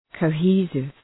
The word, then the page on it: cohesive: kəʋ’hi:sıv